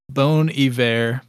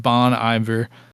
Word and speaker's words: Bon Iver: Apparently, it's pronounced Bon Iver, but I think we're all calling it Bon Iver, B-O-N space I-V-E-R.